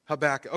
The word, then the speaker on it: Habakkuk: He said Habakkuk. So I don't know. I've always heard it called Habakkuk. How do you say it? Habakkuk. Habakkuk. Okay, it's right.